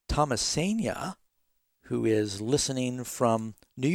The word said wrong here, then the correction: Thomasina: Did I say your name right? – It's Thomasina. – Thomasina, I'm sorry.